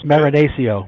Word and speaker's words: Marinaccio: My family says Marinaccio, but like all Italian families, we do argue about the pronunciation of our name.